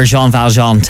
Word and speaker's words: Jean Valjean: You're Jean Valjean. Tell us all about it. – Oh, you pronounced it right.